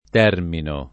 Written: termino

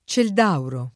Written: Cieldauro

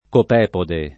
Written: copepode

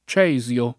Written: Cesio

